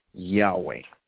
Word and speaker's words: Yahweh: So most Old Testament scholars believe that it would have been pronounced Yahweh.